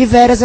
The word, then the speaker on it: Veras: Lembrando que, para quem não sabe escrever meu nome se escreve W-I-L-L-I-A-M, de Maria, ok? William. E Veras é normal, V-E-R-A-S.